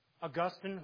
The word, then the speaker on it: Augustine: Augustine, whom I referred to at the beginning, using the proper pronunciation, he actually lived a life.